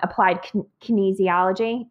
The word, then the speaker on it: applied kinesiology: And you do something different, and I love it because you use, I always pronounce it wrong, applied kinesiology. Is that, did I say that right? – Yeah.